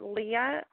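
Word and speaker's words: Lhea: And then you can get a hold of me at Leah Alicia, which my name is spelled a little funky, so it's L-H-E-A. Alicia is A-L-I-S-H-A.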